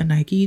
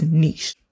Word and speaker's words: niche: You might have heard somebody call it a niche, a Nike, child, I don't heard it all. That is not the correct pronunciation. It is niche. Say it with me. Niche.